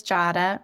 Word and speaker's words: Jadah: As you mentioned, my name is Jadah, rhymes with Prada, to help people remember.